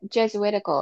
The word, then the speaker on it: Jesuitical: I'm not pronouncing that right either. Jesuitical. How do you say that? – Jesuitical, I thought. – That's right. That's right. Yes, I think you're right.